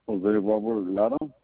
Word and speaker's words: Jose Robert Lara: Jose Robert Lara. – Can you spell it for me? – J-O-S-E, and then Robert, and then Lara, L-A-R-A.